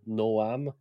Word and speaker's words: Noam: Puis eux autres, ils ont fait un jeu de mots avec son prénom, Noam, Gnome, que quand tu le prononces en anglais, ça sonne comme un gnome.